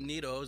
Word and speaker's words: Neto: It'd be real neato if you pronounce it Neto.